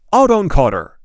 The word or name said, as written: autoencoder